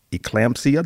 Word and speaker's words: eclampsia: Her death was ruled natural causes due to possible complications, including respiratory distress and eclampsia.